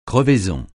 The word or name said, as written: crevaison